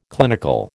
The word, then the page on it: clinical: American English